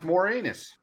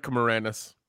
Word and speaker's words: Moranis: No, but I thought his last name was pronounced Moranus. – listen. There's only one man more anus than I am, and we know his name. – Channel Dad. – Did I pronounce his name wrong? – Yeah, that's also true. – Yeah, it's Rick Moranis.